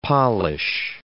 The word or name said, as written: Polish